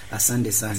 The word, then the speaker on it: Asante sana: Asante sana. – Asante sana.